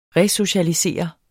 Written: RESOCIALISER

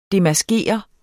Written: DEMASKER

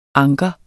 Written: anker

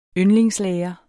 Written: yndlingslærer

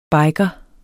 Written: BIKERE

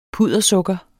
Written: puddersukker

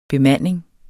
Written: bemanding

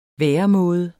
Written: væremåde

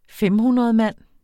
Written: femhundredmand

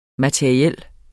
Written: materiel

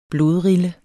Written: blodrille

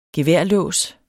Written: geværlås

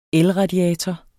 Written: elradiator

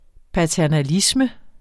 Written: paternalisme

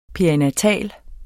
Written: perinatal

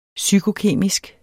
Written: psykokemisk

